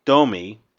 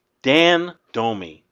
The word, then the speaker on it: Domme: So, a listener, Dan Domi, spelled D-O-M-M-E. – Mm-hmm. – Right. – But it's pronounced Domi. Dan Domi.